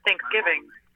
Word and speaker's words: Thanksgiving: I think whoever's putting the latter emphasis is weird because it's just Thanksgiving. It's all one emphasis. There's no emphasis on the... first half.